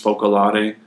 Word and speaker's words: Focolare: Focolare.